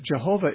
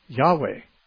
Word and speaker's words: Jehovah: More than that, Jehovah isn't even a correct pronunciation. In Hebrew, it'd sound more like Yahweh. And that's probably not even exact. But it's closer. Jehovah is in Hebrew, there's not even a J sound.